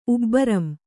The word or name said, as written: ಉಬ್ಬರಂ